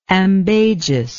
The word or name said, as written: ambagiousness